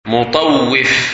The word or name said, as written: Mutawwif